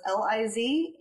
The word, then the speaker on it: Lisabeth: It's Lisbeth without the E at the beginning, so it's Lisabeth.